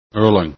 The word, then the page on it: erlang: english